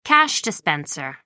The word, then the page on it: cash dispenser: American English